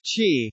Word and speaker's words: Qi: WPC developed the most widely used wireless charging standard, Qi, pronounced Qi, which allows inductive or pad-style charging as well as short-distance 1.5 cm electromagnetic resonant charging.